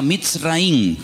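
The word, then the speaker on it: Mitzraim (מצרים): Entonces él toma al niño, toma a la madre y se van al extranjero, concretamente al país de Egipto, que en la Biblia se pronuncia Mitzraim.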